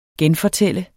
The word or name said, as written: GENFORTALT